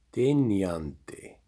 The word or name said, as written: Dynjandi